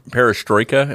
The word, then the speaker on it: perestroika: It was inspired by the revolutions of 1989 in Europe, particularly perestroika, am I saying that right, in the Soviet Union, perestroika in the Soviet Union? – Yep. Mm-hmm.